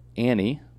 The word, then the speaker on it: Annie: News Channel 8, and she's Andy. – Like, Annie. – Andy Sabo? – Annie. – Andy Sabo. – A-N-N-I-E. – How do you say that? – Annie. – N-I. – No. – Annie. Got it.